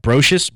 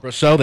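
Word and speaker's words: Brosseau: How do you say that? Brocious, Brocho, Brosso, Brosso. There we go.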